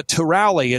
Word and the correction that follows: Tulare: it's Tulare. It's just outside of Fresno. I'm still working on the pronunciation.